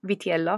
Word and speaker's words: Vitiello: Vitiello with a V, Vitiello, if I'm saying it properly.